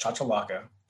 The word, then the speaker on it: chachalaca: It's called a chachalaca, and it's not a made-up word. It's C-H-A-C-H-A-L-L-C-A, I think. I'm sure I butchered that. It's called chachalaca.